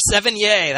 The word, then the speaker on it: Sevigny: Or Sevigny. That's how you say it. Chloe Sevigny.